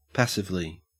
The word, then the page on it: passively: Australian English